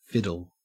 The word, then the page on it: fiddle: Australian English